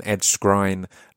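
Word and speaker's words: Ed Skrein: My name is Scroobius Pip, and I'm joined this week by the amazing Ed Skrine. That's how you pronounce his name. I got it wrong in previous podcasts, but he corrected me at the beginning of this podcast.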